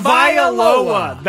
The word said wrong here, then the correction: Tagovailoa: That's one of the worst pronunciations I've ever heard. – That is wow. – Hey, you know what's dangerous about his last name? As you hear somebody mess it up once, it's very easy to start repeating the mess up.